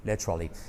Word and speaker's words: literally: Por cierto, pronunciación, letra, qué buena letra, y luego li como Bruce Lee, literally.